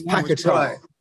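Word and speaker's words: Paquetá: Accent on the final A. – I'm tempted to try and find it. It's Paqueta.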